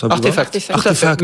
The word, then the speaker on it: RTFKT: Nike a dévoilé sa paire de chaussures Move to Earn en partenariat avec RTFKT, je dis comme ça. – Artefact. Mais ça s'écrit comme ça. J'ai dit RTFKT au début, c'était terrible.